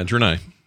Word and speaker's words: Draenei: I was playing Dr- uh, Draenei. – Yeah, I'm saying that right. Yeah, Draenei.